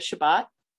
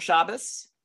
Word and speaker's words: Shabbat: Well, in the short version is that Friday night after sundown is the beginning of the Jewish holy day of Shabbat, or Shabbos, depending on how you pronounce it.